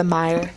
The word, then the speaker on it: Meijer: We just got the Meyer. – That's true. – The Meyer? – Yeah, it's spelled like M-E-I-J-E-R.